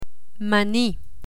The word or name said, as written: mani